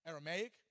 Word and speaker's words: Aramaic: how do you say it in English? Aramaic, there you go. I know in Spanish it's Arameo.